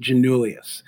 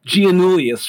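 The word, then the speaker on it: Giannoulias: I want to stress this, that when you think, and this is your mnemonic for this, when you think of Alexi, think of him holding a martini with gin in it. So you think like Alexi, you know, he's got a little bit of a James Bond look to him. Janulius. That's how you say his name, okay?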